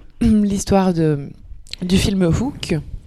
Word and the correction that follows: Hook: Hook, pardon. Euh, le H se prononce en anglais.